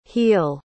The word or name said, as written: heall